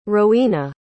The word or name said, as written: rowena